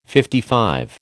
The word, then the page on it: LV: American English